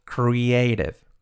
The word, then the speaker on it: creative: Cuidado esta palabra, creativo, pronunciado creative. Creative artist.